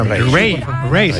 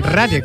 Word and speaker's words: Raye: Ray. – Se escribe Raye. Raye, con Raye, ella. Raye, ya Raye.